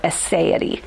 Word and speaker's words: aseity: The solitariness of God, you might find in some older theological books, but nowadays we use a different word called aseity. You might hear it pronounced aseity. It's A-S-E-I-T-Y. But it comes from two Latin words meaning by and itself, or in itself. A-S-E-I-T-Y, aseity.